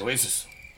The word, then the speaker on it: Nueces: I probably mispronounced that too. – Yes, you did. – Yep. Noises. How do you do that? Noises? – Noises. Yes. – Oh, okay. – I think. – Just go with that.